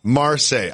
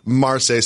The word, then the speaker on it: Marseille: Marseille. I've been corrected in my ear. Marseille. Sorry, I don't speak French.